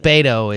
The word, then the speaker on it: Beto: I think Beto has become the most accepted way to pronounce the first name because that's what you hear all over the place.